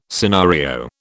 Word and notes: scenario: British English

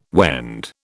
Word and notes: Wend: British English

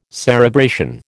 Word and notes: cerebration: American English

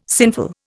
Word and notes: sinful: Indian

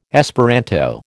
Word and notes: Esperanto: American English

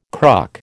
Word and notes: cruck: American English

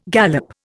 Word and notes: gallop: Indian

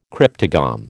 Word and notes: cryptogam: American English

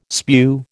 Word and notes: spew: American English

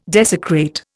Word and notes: desecrate: Indian

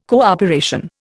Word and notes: cooperation: Indian

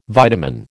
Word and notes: vitamin: American English

